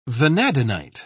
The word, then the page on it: vanadinite: British English